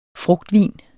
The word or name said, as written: frugtvin